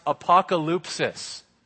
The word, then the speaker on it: apokalypsis: It comes from a Greek word pronounced apokalypsis.